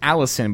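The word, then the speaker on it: Allecyn: In a complaint, I think it's pronounced Allison, but it's A-L-L-E-C-Y-N, one of those weird names.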